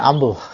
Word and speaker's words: umbel: Kan tulisannya umbel, bacanya ambel.